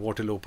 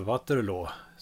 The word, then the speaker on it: Waterloo: Och som ni hörde här i den franska versionen så uttalas ju orten Waterloo på rätt sätt, alltså Waterloo som den egentligen heter.